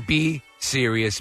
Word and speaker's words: cereus: cereus, which is a food poisoning organism. Cereus is spelled C-E-R-E-U-S. – So as in B. Serious. Be serious.